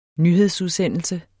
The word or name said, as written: nyhedsudsendelse